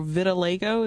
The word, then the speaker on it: vitiligo: When I first saw her design, I was a little sad because I was like, she doesn't pop off screen because her vitiligo, is that how you pronounce it? – Yeah.